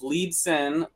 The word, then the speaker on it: Liebson: Leibson, which is my last name, is spelled LIEBSON and then BOUNDS.